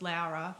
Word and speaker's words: Laura: And we were talking earlier about in Australia and a lot of English-speaking countries, it's obviously spelt Laura, but for the... you know, the point of my name, we've always insisted that it's pronounced Laura.